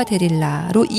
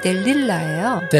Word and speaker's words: 델릴라: 참 우리는 삼손과 데릴라로 이미 익숙하지만요. – 네. – 뭐 표준 외국어 표기로는 삼손과 델릴라예요. – 델릴라. – 네, 리을이 네 개나 있으니까 발음에 주의하세요. 2막에서는 1막에서보다 훨씬 더 멋을낸 한껏 치장을 한 델릴라가 삼손을 자신의 집에서 기다리고 있어요.